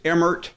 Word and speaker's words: Emmert: Did you happen to read the Washington Post article on the cluster of Mark Emmert or Emmerit or however? – Yeah, officially it's Emmert. It's very difficult to say Emmert without going Emmert.